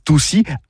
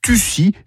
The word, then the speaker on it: toussir: En effet, en pays toussain, c'est dit toussir, autre prononciation, mais tussir, c'est de brembal au verbe tufega, sans oublier le verbe tufega pour dire également tousser.